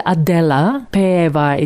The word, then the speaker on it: Adela Peeva: My name is Adela Peva. It's spelled A-D-E-L-A-P-E-E-V-A.